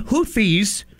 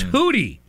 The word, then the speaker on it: Houthi: that's not apparently the correct pronunciation. You don't pronounce the TH. Mm. It's Hootie, the Hooties.